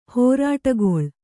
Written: ಹೋರಟೆಗೊಳ್

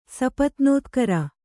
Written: ಸಪತ್ನೋತ್ಕರ

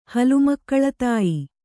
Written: ಹಲುಮಕ್ಕಳತಾಯಿ

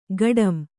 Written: ಗಾಢಂ

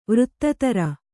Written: ವೃತ್ತಿದಾರ

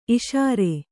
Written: ಈಸರ

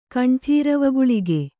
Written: ಕಂಠೀರವಗುಳಿಗೆ

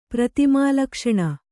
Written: ಪ್ರತಿಮಾಲಕ್ಷಣ

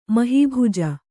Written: ಮಹಿಭುಜ

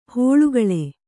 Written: ಹೊಳ್ಳುಗಳೆ

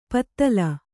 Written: ಪಿತ್ತಿಲ್

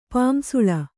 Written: ಪಾಂಸುಳ

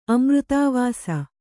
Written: ಅಮೃತಾವಾಸ

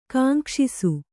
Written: ಕಾಂಕ್ಷಿಸು

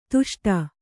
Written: ದಷ್ಟ

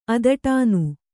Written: ಅದಟಾನು

